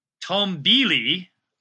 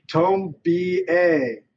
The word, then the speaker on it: Tombili: Tombili or Tombea? – It's pronounced Tombea. – Tombea, Tombea. It's spelled T-O-M-B-I-L-I.